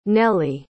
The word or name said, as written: nely